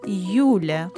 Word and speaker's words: Юля: And in the second case, Юля, the first syllable is stressed, and the word ends in Я, the last letter of the alphabet.